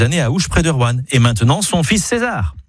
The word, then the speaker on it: Rouen: Vous avez remarqué comment je dis Rouen en une syllabe plutôt que Roanne en deux syllabes ? C'est comme ça qu'on y dit dans la région.